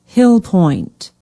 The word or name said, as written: hillpoint